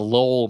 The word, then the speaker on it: Lowell: Yeah, we also did a lot of stuff like, because I'm from Massachusetts originally, and the Lowell Mills, L-O-W-E-L-L, that's how you say it, not Lowell.